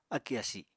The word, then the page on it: akì asi: apatani